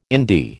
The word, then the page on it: indie: American English